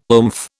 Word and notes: oomph: British English

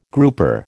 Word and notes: grouper: American English

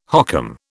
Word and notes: hokum: British English

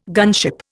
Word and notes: gunship: Indian

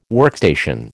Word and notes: workstation: American English

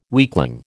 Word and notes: weakling: American English

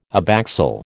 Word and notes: abaxial: American English

